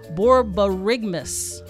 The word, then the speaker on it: borborygmus: And the scientific word for your stomach rumbling is called borborygmus. – Yeah, you said that right. – Borborygmus. Look it up. – Yeah, okay. – I'll spell it for you. B-O-R-B-O-R-Y-G-M-U-S.